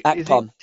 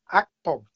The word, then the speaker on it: Akpom: Akpom. Akpom. – Akpom.